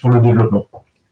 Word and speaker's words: pour le Développement: Is a senior researcher at the Institut de Recherche, I don't know if I'm pronouncing this well, Poly Development, pardon my... – It's the Research Institute for Development. We say the Institut pour le Développement.